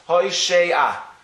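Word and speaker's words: Hoshea (הושע): Hoshea is spelled Hey Vav Shin Ayin. What nekudas are under the shin? Atzeire, there are two dots. Hoshea.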